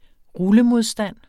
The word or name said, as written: rullemodstand